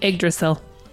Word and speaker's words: Yggdrasil: Then he tied a noose to the world free tree, and I'm not going to pronounce this. It's YGG. – Yggdrasil. – Yeah, YGGDRASIL. – Yggdrasil. She's right.